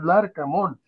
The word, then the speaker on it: Larcamón: Hablamos de Larcamón. Se llama Larcamón. Sí. Un amigo mío le dice Lacramón. Eh, Lacramón. – Tú dices Lacramón. Es Larcamón. Larcamón. Larcamón.